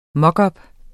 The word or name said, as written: mockup